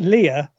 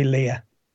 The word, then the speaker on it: Leia: Though whether it's Leia or Leia is still debated, as both pronunciations are used on screen. For me, growing up in the north of England with classmates of the same name, it will always be Leia.